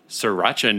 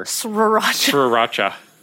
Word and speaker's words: Sriracha: I always want to pronounce the first R. – Sriracha. – Sriracha. – Yeah, I never know how to spell Sriracha. – But I'm just going to pretend it's not there.